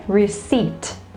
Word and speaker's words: receipt: Yeah, it is a little bit tricky, this one, because there is one alphabet which is silent. So you don't say the sound of that alphabet, which is the P sound, the P sound. So some people say receipt, but it is not, okay? You need to take out the P and then you say receipt.